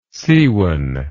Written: sewan

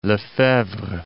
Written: Lefebvre

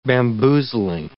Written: bamboozling

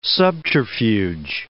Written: subterfuge

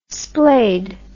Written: splayd

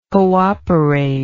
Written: cooperator

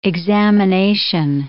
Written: examination

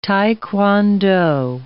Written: tae kwon do

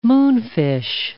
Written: moonfish

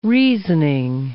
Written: reasoning

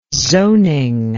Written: zoning

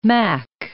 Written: mac

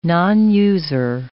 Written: nonuser

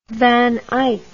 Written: van eyck

